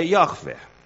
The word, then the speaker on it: Yahweh: Some, most say Yahweh. Some say Jehovah. Others, Yahuwah, or something else, some other combination of nouns where the weight or the emphasis of the expression is drawn out in different parts of the word. We're not even sure how many syllables it is, three or two, Yahweh or Yahweh or something like that.